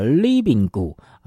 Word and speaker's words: RIBINGU: 非常活泼轻快的这首客语歌 RIBINGU 啊，是他们的团名，要怎么念呢？啊，叫 Libingu 啊，这个重音呐、啊，哎，可是你可以，哎，这个刚开始看到这英文单字 Libingu 啊。